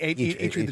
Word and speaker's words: Eitri: Eitri, how do you pronounce it? – Eitri the dwarf, yeah.